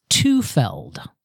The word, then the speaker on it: Tufeld: It is pronounced Tufeld, like meld.